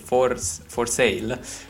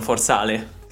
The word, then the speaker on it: For Sale: Il nome della band è For Sale, che è scritto come si dice in italiano, quindi For Sale, non For Sale.